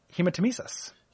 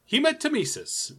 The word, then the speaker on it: hematemesis: Sure uh, number 21, hematemesis. – Hematemesis. We both said that right.